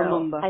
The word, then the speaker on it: Lumumba: So Kizenga's party formed a coalition with Patrice Lumumba. I don't know. – Lumumba.